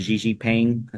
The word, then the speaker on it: Xi Jinping: Burke, did you see that Trump made a hint when he has a meeting with, I think his name is Xi Jinping, is how you say it,